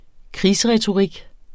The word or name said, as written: krigsretorik